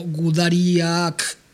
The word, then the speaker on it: Gudariak: o Gudariak, en realidad, porque lo pronuncia así en la canción.